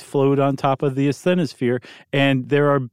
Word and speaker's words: asthenosphere: And they float on top of the asthenosphere.